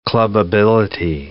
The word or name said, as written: clubbability